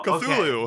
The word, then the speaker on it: Cthulhu: Well, to go on that note, Lovecraft actually wrote in a note that you're not supposed to pronounce the T. The people calling him Cthulhu instead of Kalulu is actually a more modern take on the name.